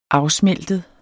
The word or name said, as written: afsmeltet